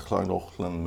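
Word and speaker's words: Llangollen: Uttalas, ja, Schlangochlen men stavas Langollen. Eh, den går in i Wales.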